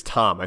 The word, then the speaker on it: Thom: Matt, I think it's Tom. I think that back in the olden days, they just spelled it T-H-O-M, but I think it's not pronounced Thom.